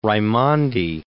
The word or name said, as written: Raimondi